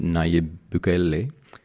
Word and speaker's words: Nayib Bukele: Apparently, the president Nayib Bukele. Probably mispronounced that horribly. – Nailed it. Yeah. Keep going. – Like a native.